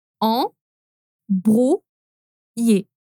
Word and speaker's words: embrouillé: É só repetir comigo em três sílabas separadas: on, bru, ye.